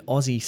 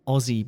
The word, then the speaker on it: Aussie: We keep the first syllable, Oz, and then we add E on the end. But don't fall into the trap of saying Aussie. When it gets shortened, the S changes into a Z sound, Aussie.